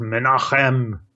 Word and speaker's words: Menahem: I think this is pronounced Menachem. I'll try to pronounce it Menachem.